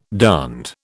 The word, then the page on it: darned: British English